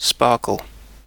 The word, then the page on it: sparkle: British English